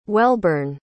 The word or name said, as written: Welburn